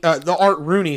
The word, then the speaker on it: Rooney: The Art Warney, Art Rooney, excuse me.